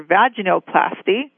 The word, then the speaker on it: vaginoplasty: designer laser vaginoplasty.